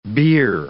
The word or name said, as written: bier